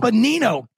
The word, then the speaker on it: Benigno: First of all, we've got Dennis Benigno. Is it? I say that right, Dennis? – Benigno. – God dang it, Eric. Good morning. Dennis. – Drew, thank you for rescuing my last name.